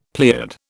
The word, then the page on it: pleiad: British English